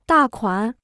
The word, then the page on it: 大款: dà kuǎn